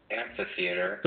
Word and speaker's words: amphitheater: You pronounce amphitheater, amphitheater. – There are H's in there.